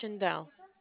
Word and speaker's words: Shaindel: My name is Shindel. – You want to spell that? – Sure. S-H-A-I-N-D-E-L.